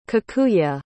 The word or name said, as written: kikuye